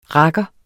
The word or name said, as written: rakker